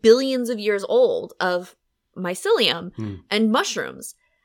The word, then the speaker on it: mycelium: And so I was watching this documentary and they were saying that mycelium is apparently how you pronounce it. I've been saying mycelium my whole life and that's not how you say it. It's mycelium.